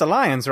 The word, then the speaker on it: Allianz: Uh, Alli- it's just Allianz, right? – Alliance.